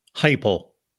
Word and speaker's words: Heupel: He didn't give me a whole lot, but how do you pronounce his last name? Is it Heupel? Heupel? – Heupel. – Heupel. Okay, I knew I was pronouncing it wrong.